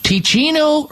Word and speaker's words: Teeccino: It's called Ticino Herbal Coffee, and it's spelled T-E-E-C-C-I-N-O.